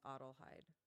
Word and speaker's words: Adelheid: Is Adelheid. – Okay, Adelheid. – Of course, yeah, mm-hmm. The very common name. – Yeah, my first name's Adelheid. – Adelheid, like Adel, like a duck waddle, and Hyde.